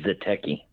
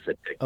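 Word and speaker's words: zeteki: Zatecki. – I'm sorry? – Yeah. It's Zatecki. – Zatecki. – Atelopus Zatecki.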